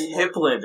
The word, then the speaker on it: Hipland: It's Hipland. There's no E.